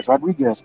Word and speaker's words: Rodriguez: So let me ask you guys, what did you guys think about Rhea Ripley against Raquel Rodriguez? I'm hoping I said that right because Rodriguez.